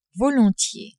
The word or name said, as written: volontiers